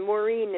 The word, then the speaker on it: Maureen: And Maureen is spelled M-A-U-R-E-E-N.